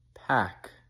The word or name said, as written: pack